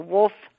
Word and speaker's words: Wulf: I've never heard of it though, but tell us the fit, the Wolf, spelled with a U, by the way, listeners, WULF, Wolf's Fish.